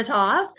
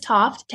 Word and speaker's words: Toft: You can look for me on IMDb under Shanna Toft. And my first name phonetically is Sierra Hotel Alpha November November Alpha, and then my last name is Toft Tango Oscar Foxtrot Tango.